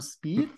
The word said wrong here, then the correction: Speight: Spate. – Tom Spate.